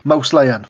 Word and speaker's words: Mousillon: I was about to say, I, I think it could be Mousillon. That's how I'd pronounce it, but then I think my teeth fell out again. – Mouse Lion. Mouse Lion, that's it.